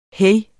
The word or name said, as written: hej